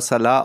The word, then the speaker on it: Saleh: Ähm, hat natürlich äh, zu tun mit dem Head Coach Robert Salah, wird Saleh geschrieben, aber Salah ausgesprochen und den würde ich euch gerne noch mal kurz ein bisschen vorstellen, weil das ist eine spannende Personalie.